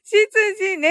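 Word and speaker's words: 執事: そしたら、しず、ひじね。しつじね。しつじだったのにね、羊って読んじゃってね。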